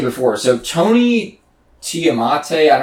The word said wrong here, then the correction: Tulathimutte: Tulathimutte, yeah.